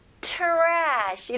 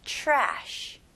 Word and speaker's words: trash: 네, 발음은요, 여기서 tr로 시작하지만, 여기서는 chr. 이렇게 발음하시면, 훨씬 더 발음하기 쉬울 거예요. 그래서 trash. 이렇게 하는 것보다는 ch 발음으로 시작해서 trash. trash. Very good. 이름절로 하셔야 됩니다. trashy가 아니고요. trash. Okay.